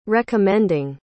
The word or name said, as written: recommending